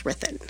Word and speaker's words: Ruthin: R-U-T-H-I-N. It's pronounced Rithin. – Because it's Welsh. – It's Welsh.